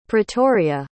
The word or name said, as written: Pretoria